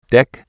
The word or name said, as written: deck